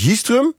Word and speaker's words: Jistrum: Ervaringsdeskundige Rinse Pol, 65 jaar, uit Jistrum. Zeg ik dat goed zo, Rinse? – Ja, klopt.